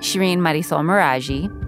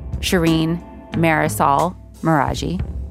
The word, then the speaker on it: Shireen Marisol Meraji: This is how somebody who probably doesn't speak any Spanish and doesn't know any Farsi would say my name. Shireen Marisol Meraji.